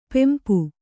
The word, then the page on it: ಪಂಪ: Kannada